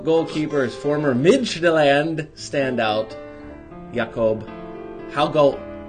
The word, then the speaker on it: Jakob: It's J-A-K-O-B, simple enough. The J in Danish is pronounced as a Y, so Jakob is what you're going to get in the first name.